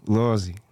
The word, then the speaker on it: Lawsy: I'm hoping that I say it right the first time. Lawsy. – Hell yeah, Lawsy. – There we go, okay.